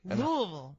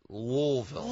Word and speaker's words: Louisville: Louisville. – Louisville. – Louisville. – You just kind of roll it. Louisville.